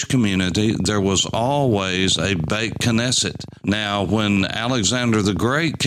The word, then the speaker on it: Beit Knesset: And when you put the word Beit with it, which is the word for house, Beth, as we would call it, B-E-T-H, Beth Knesset or Beit Knesset, as it's pronounced in modern Hebrew, means the house of assembly.